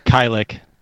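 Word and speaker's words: Kajlich: The main character is Sarah Moyer, played by Bianca Kajilich. I'm hoping I pronounced that right. – Kylik. – Oh, it's pronounced Kylik? – It's Kylik.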